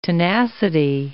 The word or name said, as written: tenacity